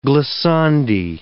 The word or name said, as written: glissandi